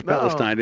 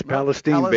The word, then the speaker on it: Palestine: It like we think the Old Testament says Palestine. No, it's Palestine, baby.